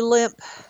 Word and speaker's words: Lemp: It would be Limp Mansion in St. Louis. It's spelled L-E-M-P.